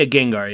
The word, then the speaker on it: Gengar: Is it Gengar? – Yeah, Gengar. Yeah, he's the the purple ghost Pokemon. Yeah. – Okay, so you have what I'm assuming is the world's largest Gengar collection.